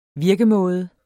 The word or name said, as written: virkemåde